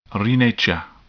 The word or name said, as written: renature